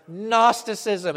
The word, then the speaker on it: Gnosticism: In the second century, it's going to be a full blown heresy and it's going to get a fancy name Gnosticism. Obviously spelled with a G, Gnosticism.